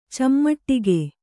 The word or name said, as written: ಚಿಮುಟಿಗೆ